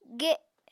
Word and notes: ge: Apatani

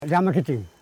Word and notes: dgamakiting: dga.ma.ki.ting

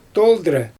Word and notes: toldrɛ: Xyzyl

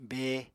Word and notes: be: Apatani

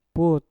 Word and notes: put: Mpur

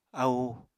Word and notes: ahu: Apatani

